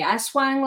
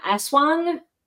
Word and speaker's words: Aswang: Close. I'm probably butchering the pronunciation. Aswang, Aswang.